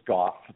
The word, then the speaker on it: Gough: Sure, it's Gough.